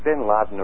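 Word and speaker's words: Bin Laden: Is the correct pronunciation of his name Ben Laden? – It's Ben Laden. It doesn't really matter.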